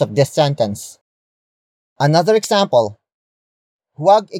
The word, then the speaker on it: huwag: Huwag ikagalit ang opinyon ng iba.